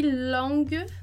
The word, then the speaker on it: langue: E esse E, ele é mudo no final. Então, langue, langue, ok? Como você pode imaginar que a palavra acaba, na verdade, antes do E.